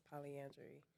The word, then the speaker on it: polyandry: There's polyandry. And I'm saying it right, andry.